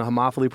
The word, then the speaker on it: homophily: Well done on the homophily pronunciation.